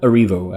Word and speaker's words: Erivo: And then, which her name is Cynthia Erivo. I hope I pronounced that correctly. – No, I think you nailed it.